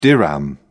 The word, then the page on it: dirham: British English